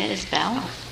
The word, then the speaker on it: Isbell: My name is Gina Isbell. – Isbell, okay. Mm-hmm. – Just like it's spelled.